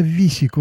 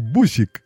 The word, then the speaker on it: бусик: Ну, потому что «Б» немецкая, как русская «В» на письме пишется, «У» как наша «И», а дальше «СИК» написано, «С-И-К». Висик, висик. Пока, наконец-то, там во второй переписке или еще что-то не стало понятно, что это все-таки бусик.